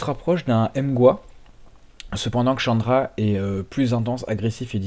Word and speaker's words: Khandra: Le groupe a un nom qui s'écrit Khandra, K-H-A-N-D-R-A. Et il semblerait que cela se prononce Chandra.